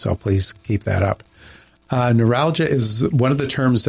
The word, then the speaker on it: neuralgia: Neuralgia.